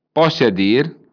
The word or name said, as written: I permëtte